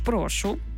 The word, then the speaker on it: прошу: Насправді плутанина слів «прошу» і «прошу» є вкрай поширеною. «Прошу» з наголосом на перший склад – це вигук.